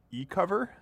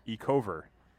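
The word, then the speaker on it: Ecover: First, I washed them normally because I have good luck with my combo of E-cover. Is that the right pronunciation of that? – Yeah, E-cover.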